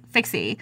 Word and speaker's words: Fikse: It's five letters and it's spelled phon- it's pronounced phonetically, Fixie. Fixie.